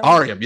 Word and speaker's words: Aureum: Aureum, yeah.